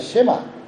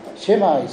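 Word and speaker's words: シェ マ (שמע): 聞けという言葉はヘブライ語ではシェマというふうに言います。シェマイスラエルというふうに発音します。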